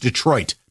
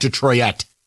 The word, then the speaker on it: Detroit: It's Detroit, not Detroit. – Detroit? – To the new Thanksgiving. Detroit. Say it right.